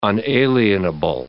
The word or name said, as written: unalienable